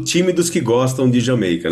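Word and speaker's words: D'yer Mak'er: Agora, eu sou do time que gosta muito da música Jamaica, ou que se a gente lê com uma pronúncia mais aproximada da nossa, o pessoal talvez não entenda, né? O pessoal, Dire Maker, né? Que a gente geralmente fala, mas é Jamaica, claro, é para soar como Jamaica, afinal é um reggae, né?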